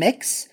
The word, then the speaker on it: Mx: So, for instance, my honorific is MX period, which is pronounced mm-hmm. mix, um, or just, you know, straight up, the gender options are just male, female.